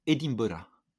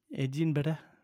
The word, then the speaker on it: Edinburgh: Écossais disent Edinburgh. – Edinburgh.